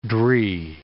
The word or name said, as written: dree